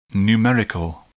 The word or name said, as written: numerical